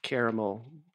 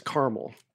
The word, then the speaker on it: caramel: The South and Upper East Coast have three syllables in caramel. The rest of the country says caramel. – Caramel? – Two syllables.